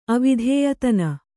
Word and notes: ಅವಿಧೇಯತನ: Kannada

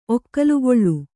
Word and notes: ಒಕ್ಕಲುಗೊಳ್ಳು: Kannada

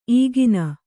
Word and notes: ಈಗಿನ: īgina

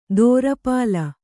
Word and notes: ದೋರಪಾಲ: dōrapāla

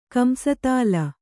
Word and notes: ಕಂಸತಾಳ: Kannada

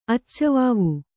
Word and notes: ಅಚ್ಚವಾವು: Kannada

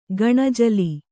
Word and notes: ಗಣಜಿಲೆ: gaṇajile

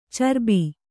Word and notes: ಚರಬಿ: carabi

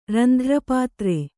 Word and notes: ರಂಧ್ರಪಾತ್ರೆ: randhra pātre

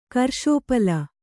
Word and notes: ಕರ್ಷೋಪಲ: karṣōpala

